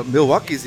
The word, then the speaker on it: Milwaukee: You know, Milwaukee's the only city that's elected a socialist mayor twice. – It's pronounced Milwaukee.